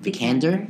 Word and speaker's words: Vikander: Vikander? – Yeah.